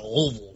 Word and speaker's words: Louisville: You got to say it like you have peanut butter stuck on the roof of your mouth. Louisville.